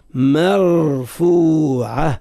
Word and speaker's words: مرفوعة: وعلى مستوى التلفظ بحرف الراء من كلمة مرفوعة يجب أن نفخم صوت الراء مرفوعة من غير أن يتأثر حرف الميم بتفخيم الراء فنفخم صوته هذا خطأ. ما مرفوعة والفاء زاد مرفوع فو مرفوعة من غير تكلف ومن غير مبالغة